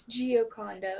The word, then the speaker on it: Giocondo: It's Giocondo.